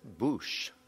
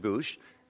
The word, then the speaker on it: Boix: His name, you can see in the corner, is B-O-I-X, and I was just taught a few moments ago that he pronounced it Bouche, which is a strange way to pronounce it to French ears anyway, but anyway, that's how it's pronounced, Richard Bouche.